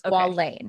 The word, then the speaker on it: squalane: S-Q-A-L-A-N-E. Right. Sorry. – So it is the A. – Squalane.